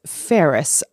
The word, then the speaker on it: Ferris: Ferris, my last name, to tie them all together. So that is spelt F for frog, E-R-R-I-S for sugar, or Ferris like Bueller, or Ferris like the wheel.